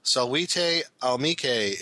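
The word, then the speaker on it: Salvete amici: To help for future reference, the greeting is pronounced Salvite Almike.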